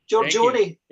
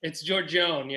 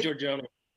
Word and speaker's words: Giorgione: Giorgione. – It's Giorgione, yeah. You can say Giorgione, but it's... In the Italian, in the old country, you say Giorgione.